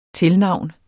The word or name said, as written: tilnavn